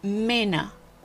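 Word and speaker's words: Männer: Piensa que cuando la A tiene esos dos puntitos arriba, tú tienes que leerla como una E. Entonces, un ejemplo sería hombres, mena. En alemán, hombres, mena. Y se escribe con esa A con dos puntitos.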